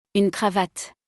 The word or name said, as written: Une cravate